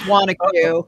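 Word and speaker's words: Wanaque: Oh, which is, I pronounced it Wanak. It's Wanacue? – It's Wanacue. Yes, we hear all, no, you can't know unless you're in the area.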